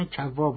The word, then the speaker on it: کوا: ظاهرا کوا باید تلفظ کنیم، عبدالله ابن کوا یشکری بوده.